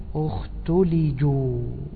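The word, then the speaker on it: اختلجوا: طبعا هذه الكلمة صعبة في النطق، ونستحضر هنا ما يقول أهل القراءات من الضم والإرجاع. عندك ثلاث ضمات بينهم سكون وكسرة، فلا بد أنك تضم الشفتين وترجع وترجع تضم حتى تأتي بها، مثل أمتحشوا أختلجوا.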